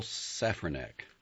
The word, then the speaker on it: Safranek: The hero of Downsizing is called Paul Safranek, Matt Damon, and the clumsy name befits him. He stresses the first syllable, but everyone else stresses the second, with a long A.